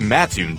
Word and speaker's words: Mattoon: It's Mattoon, dummy.